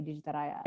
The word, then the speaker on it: Digitaraya: Well, I try to say it so that you can kind of hear how it's spelled. Like Digitaraya. Yeah, because in Indonesia, like we'd say Digitaraya.